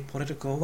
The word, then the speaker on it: political: ちなみに political は political ですから LI の I の上というふうに全てアクセントがずれます。